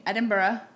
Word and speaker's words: Edinburgh: And then how would you say? Edinburgh. – Edinburgh. – Well, Edinburgh. – Like Pittsburgh.